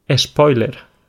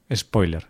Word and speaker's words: spoiler: No lo usábamos y de nuevo repetimos aquí que, estas son las típicas palabras que además las pronunciamos con una E al principio. Añadimos esa vocal delante de la S y no decimos, eh, no lo decimos de la manera más apropiada en inglés, sino que decimos spoiler.